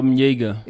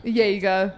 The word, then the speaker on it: Jaeger: Jaeger. – Jaeger. That was his story, you know? There we go. – I hope that's how you pronounce it. – Sounds right.